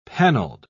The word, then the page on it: panelled: British English